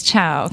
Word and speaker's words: Cao: I didn't change it for my new job, but it's spelled C-A-O, which is the Mandarin spelling of the chow, C-H-O-W. So it could have been C-H-O-W, then it would have been...